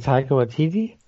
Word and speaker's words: Taika Waititi: You mean Taika Waititi? – Thank you.